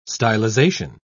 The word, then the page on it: stylisation: British English